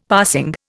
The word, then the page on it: passing: Indian